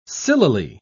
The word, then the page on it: sillily: British English